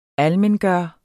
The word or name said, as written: ALMENGJORT